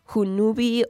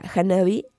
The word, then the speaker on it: Hanabi: Fíjense que yo no sabía cómo pronunciar su nombre, porque las As están invertidas, perdón, y son como unas Vs. Entonces no sabía si era Hunubi o Hububuni o Hanabi. pero bueno, ya después lo investigué y ya vi que sí se pronuncia Hanabi.